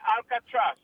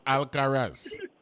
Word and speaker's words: Alcaraz: What rule am I violating, Mr. Ranking Member? – the rule of pronunciation. – His name is Carlos Alcaraz, right?